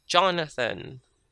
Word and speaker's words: Jonathan: Instead of Jonathan, the TH, instead of a TH sound, we use a T sound, Jonathan.